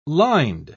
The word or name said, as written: lined